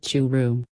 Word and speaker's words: Churu: A journey like no other, that is how I would describe my first ever trip to the gateway of Thar Desert, Churum, pronounced Churum.